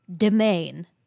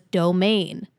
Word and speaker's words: demesne: I Googled this word, and it is pronounced demain. Comes from French. I've heard the phrase master of his own domain before, but I've never seen it written. I always thought it was domain.